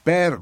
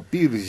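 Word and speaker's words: Peer: Per y no Pir como pronuncian los que saben lenguas extranjeras.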